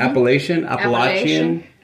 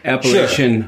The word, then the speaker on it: Appalachian: Appalachian.